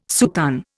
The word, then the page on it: soutane: Indian